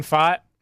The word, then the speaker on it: Pfaadt: Brandon Pfaadt. – Pfaadt. The guy's name is spelled P-F-A-A-D-T.